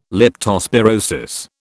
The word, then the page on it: leptospirosis: British English